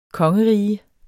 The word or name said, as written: kongerige